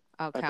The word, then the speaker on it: Alcala: Rodney Alcala. Alcala. I believe it's pronounced.